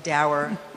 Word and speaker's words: dour: in a dark and dour or dour, depending on how you say it. I think it's dour, but anyway. Dour, I say it dour.